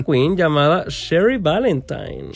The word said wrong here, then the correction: Cherry Valentine: Y Cherry, Cherry Valentine, su nombre es George Ward, tiene 29 años y viene de Darlington, UK.